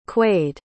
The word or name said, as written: quaid